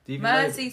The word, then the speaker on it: Mersea: Mersey spelled like sea.